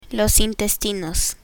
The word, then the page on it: intestinos: Spanish